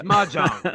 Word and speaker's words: Mahjong: Is that how it's Mahjong. Pronounced? They go to playing? It's yeah. Mahjong.